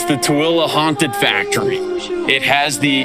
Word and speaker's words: Tooele: This is Tooele. – Tooele. – Zach, remember it like this. Tooele, you go to the store with me later? – You know what?